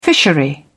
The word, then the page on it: fishery: British English